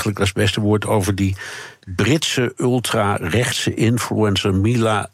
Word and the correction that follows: Milo: Oh ja, ik sla altijd zijn achternaam over. En hier zegt iedereen altijd Milo. – Ja, Milo, ja, zo noemt je zelf.